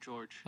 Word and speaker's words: Jorge: Isn't it Jorge Masvidal? Uh, he pronounced it George.